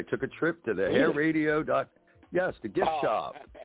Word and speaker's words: hairadio: It's pronounced Hair Radio. It has just one R in the middle.